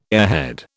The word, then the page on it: airhead: British English